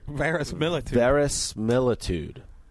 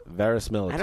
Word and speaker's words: verisimilitude: Verisimilitude. – That's how you say it. Verisimilitude.